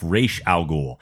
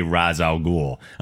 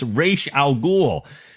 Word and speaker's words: Ra's al Ghul: He knows how to say it. He says it properly. And everyone continues to say Ra's al Ghul. I'm like, God damn it. You heard him say it himself. It's Ra's al Ghul.